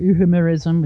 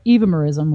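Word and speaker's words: euhemerism: And, you know, it's pronounced either evemerism, which is difficult to pronounce, or evemerism, which I've simplified it by giving it that pronunciation.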